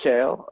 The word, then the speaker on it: Kjael: And that's it's pronounced Kjael.